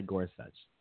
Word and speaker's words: Gorsuch: Neil Gorsuch. Gorsuch? Gorsuch. – Let's say Gorsuch.